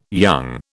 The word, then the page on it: young: British English